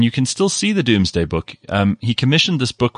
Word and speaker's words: Domesday: They say Doomsday, but Doomsday, you spell it Domesday Book, yeah.